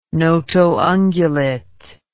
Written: notoungulate

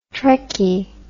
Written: trekkie

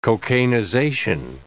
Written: cocainization

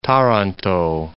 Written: Taranto

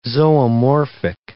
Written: zoomorphic

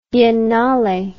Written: biennale

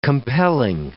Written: compelling

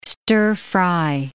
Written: stir-fry

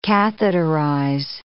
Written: catheterize

